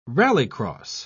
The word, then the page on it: rallycross: British English